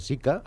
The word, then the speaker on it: Zika: De fet, la denominació Zika prové d'un topònim, que és el bosc de Zika, a Uganda, on es va identificar per primera vegada aquest virus l'any 1947. La pronúncia és plana, Zika, no pas Zika, i la normativa prefereix que en diguem virus del Zika o també de Zika.